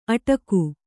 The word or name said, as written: ಅಡಗು